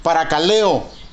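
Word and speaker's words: paracaleo (παρακαλέω): Quiere decir la palabra consolado en su original griego se pronuncia paracaleo.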